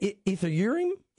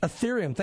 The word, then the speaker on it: Ethereum: Bitcoin, initial coin offering, and how do you say that? – Ethereum. – Ethereum. Thank you.